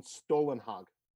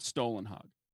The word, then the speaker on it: Stålenhag: So you pronounce it Stålenhag. – Yeah, because the O in those languages is more like an oo sound.